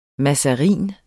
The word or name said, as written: mazarin